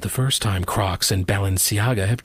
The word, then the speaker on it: Balenciaga: TJ, you know how to pronounce this word, right? Balenciaga. You're a big fashionista. I think TJ knows this stuff. – Yeah, you said it exactly right. How I would have said it.